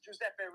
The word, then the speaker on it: Giuseppe: Ryback, my name is Giuseppe, but it's really Joseph in English for you. – Joseph, what is it? Giuseppe? Is that how you pronounce it? – You could say that. Yes, it's Giuseppe, really.